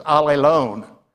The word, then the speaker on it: allelon: Now, that word translated each other in the Greek, if I were to pronounce it with anglicized letters, as allelon.